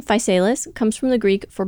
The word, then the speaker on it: Physalis: Looked up the pronunciation of that several times. Yes, that is how they say to say it, Physalis.